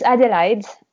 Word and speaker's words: Adelaide: Ah, it's Adelaide, like the city in Australia.